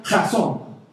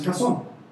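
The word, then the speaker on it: jazón (חזון): Esta palabra visión, que está escrita ahí en Proverbios 29 y 18, es una palabra hebrea que se pronuncia jazón. Sí, así como lo estoy pronunciando, jazón. Pero si toda la vida dijéramos jazón, toda la gente andaría escupida aquí en la parte de atrás, sería bastante incómodo. ¿Te imaginas aquí, gracias? Así se llamaba Jason. Gloria a Dios, hermano. O sea, qué unción que debe haber en ese pueblo. Se pronuncia así, Jason, ¿sí? Pero por temas convencionales, ¿sí? Nosotros la pronunciamos Jason.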